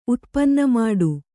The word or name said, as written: ಉತ್ಪನ್ನಮಾಡು